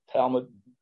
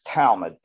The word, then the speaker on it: Talmud: The law, the explanation of the law is a book called, I think I'm pronouncing this correct, Talmud. Is that correct? Talmud. So it's Talmud.